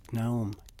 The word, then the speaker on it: gnome: what am I gonna do with this gnome?" The G is silent.